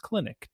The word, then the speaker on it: Clynick: Again, C-L-Y-N-I-C-K, pronounced Kleinick.